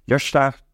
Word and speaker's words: Jørstad: So, as you say, my name is Harald Jørstad. The Norwegian name would be Jørstad to pronounce it.